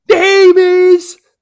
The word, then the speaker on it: James: You thought James was a one syllable word name. It is not. Here in Brazil, it's two.